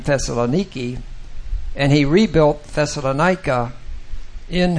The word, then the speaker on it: Thessaloniki: In Greece, they pronounce the city Thessaloniki.